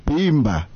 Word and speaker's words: Bimba: Our word for today actually rhymes with Simba. Mm-hmm. It's Bimba.